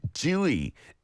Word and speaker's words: Tzuyu: I always get confused with the English T-Z-U-Y-U. Give us your tips on how to pronounce that better. Thank you very much. Dewey is the pronunciation in Hangul. Dewey, my producer Minnie has just written it down. That is no way near the romanization, right? Tzu-yu. Interesting. Dewey.